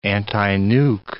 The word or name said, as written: antinuke